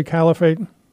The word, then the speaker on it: Caliphate: Am I pronouncing that correctly, Caliphate? – You are.